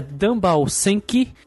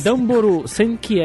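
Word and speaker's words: Damboru Senki (ダンボール戦機): O nome do jogo é Dumbal Senki, conhecido aqui no ocidente como Little Butler's Experience. na verdade a pronúncia é Damboru, Damboru Senki, em japonês significa algo como as máquinas uh, lutadoras, máquinas de guerra da caixa de papelão.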